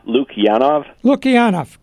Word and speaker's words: Lukianoff: Uh, once again, my guest has been Greg Lukinoff, and no, pronounce it for me. – it's uh, Luke Yanov, great.